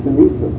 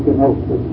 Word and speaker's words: kenosis: And our word, the Greek word is kenosis, or kenosis, as it's spelled in our dictionary, where the Greek pronounces kenosis.